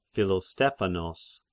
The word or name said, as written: Philostephanos